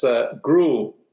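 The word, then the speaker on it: Groulx: It's Grou is how it's pronounced,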